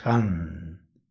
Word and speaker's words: Hang: El mantra es Han. Se escribe H-A-N-G.